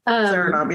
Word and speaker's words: Suriname: So, and I want to make sure, because I think I'm overpronouncing it, Suriname. – Suriname, yeah.